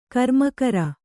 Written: ಕರ್ಮಕರ

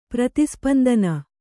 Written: ಪ್ರತಿಸ್ಪಂದನ

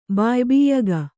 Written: ಬಾಯ್ಬಿಯ್ಯಗ

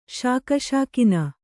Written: ಶಾಕಶಾಕಿನ

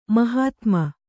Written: ಮಹಾತ್ಮ

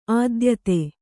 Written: ಆದ್ಯತೆ

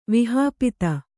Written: ವಿಹಾಪಿತ

